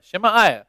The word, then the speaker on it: Shemaiah: Shemaiah.